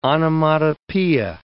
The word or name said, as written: onomatopoeia